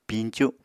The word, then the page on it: piñcu: Apatani